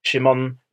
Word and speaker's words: Shimon: And if you read the texts of letters which have been found in the Judean desert, in the Judean caves, his name is Shimon ben Kokhba. And notice it's a soft S.